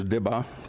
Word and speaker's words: dibbah: best I can do for pronunciation is dibbah, D-I-B-B-A-H, once it's anglicized, whatever.